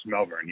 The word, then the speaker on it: Malvern: You've got Malvern, Iowa. Did I say that right? – Yes, Malvern, yeah.